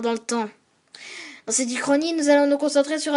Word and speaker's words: Uchronie: Et Uchronie s'écrit U-C-H, qu'on prononce K, comme dans chorale. Donc U-C-H-R.